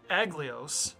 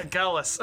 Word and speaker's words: Agalus: The name of the game is Aglios. – Aglios? – Oh my goodness. No. Agalus. – Agalus. – The emphasis is on the second syllable.